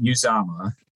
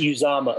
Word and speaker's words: Uzomah: I'm going to have you help me pronounce the name. Azoma is what I can get, but how about taking care of the rest of it? – I believe it is Felix Anudike Uzama. – Is Uzama, okay. – I believe that's how his parents pronounce his name.